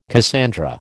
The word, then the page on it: Cassandra: American English